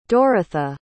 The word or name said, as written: Doretha